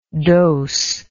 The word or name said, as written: dosed